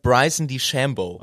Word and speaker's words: DeChambeau: Bryson DeChambeau, spricht man ihn so? – Ja, die Amis sagen Bryson DeChambeau.